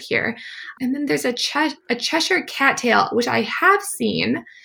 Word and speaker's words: Cheshire: I always just say Cheshire, which is I think how the English would pronounce it.